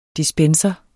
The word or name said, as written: dispenser